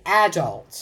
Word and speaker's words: adult: Okay, the British pronunciation tends to be adult.